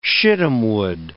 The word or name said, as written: shittimwood